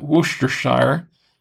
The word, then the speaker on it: Worcestershire: It's spelled Worcestershire, but it's people pronounce it Worcestershire.